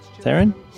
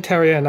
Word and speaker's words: Therrien: On today's show, Blockhouse leader Alain Therrien. Therrien? – Alain Therrien, I think, is how it's pronounced. – There we go.